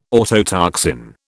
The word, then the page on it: autotoxin: British English